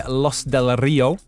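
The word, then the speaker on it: Los del Río: The song by Los Del Rio, the Spanish duo. – That was much better than my pronunciation earlier. – I did do Spanish at school, so I can do the rolled R's, yeah.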